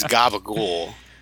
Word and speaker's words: gabagool: The next one is Guga Gager. – I'm sorry. I think you were trying to say something in Italian. Let me correct your pronunciation there. It's gabagool, is what you're trying to say.